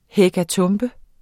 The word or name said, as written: hekatombe